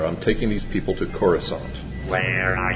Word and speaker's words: Coruscant: The only question was, how do you pronounce it? Uh, dude, it's pronounced Coruscant. – No way, man, it's pronounced Coruscant.